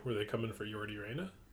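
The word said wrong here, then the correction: Yordy Reyna: Well, you've pronounced his name wrong again. But, it was a good joke overshadowed by your inability to pronounce the poor man's name right. And you best believe in that courtroom they get it right. – You think so? – They say, Jordy, mate, can you please, Jordy Reyna, please rise so we can read the charges against you.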